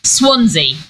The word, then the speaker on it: Swansea: But he's in yeah he's in is it Swansea? Is that how you say it? Swansea, yes.